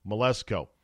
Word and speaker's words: Mleczko: Melezko, I believe is how you pronounce her last name. M-L-E-C-Z-K-O. Melezko.